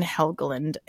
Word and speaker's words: Helgeland: So our writer and director is Brian Helgeland, and you would think that having done this twice now I could ensure that I had pronounced that correctly, have not. Was that right? Okay, that was correct. Yes.